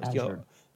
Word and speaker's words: Azure: The Teams, the Azure, I can't even pronounce that right. I know it's blue in Spanish. I thought it was. – Azure.